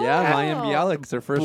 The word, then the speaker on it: Mayim Bialik: Mayim Bialik. – Mayim Bialik, yes.